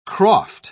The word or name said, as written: croft